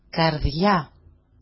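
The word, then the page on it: καρδιά: kar5ja-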